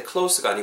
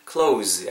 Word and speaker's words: close: 그리고 close 발음하실 때 close가 아니고 이 경우에는 close 약간 z 발음을해주셔야 된다라는 거.